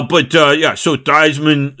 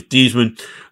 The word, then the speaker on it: Theismann: So Theismann, whose real name was not pronounced Theismann because he tried to win the Heisman, is really Theismann.